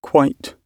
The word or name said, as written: quite